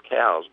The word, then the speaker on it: Coues: They were named after a surgeon, a cavalry surgeon many years ago, whose name was actually Elliot Cowes, but it's spelled C-O-U-E-S, and most people these days pronounce it as Coos deer.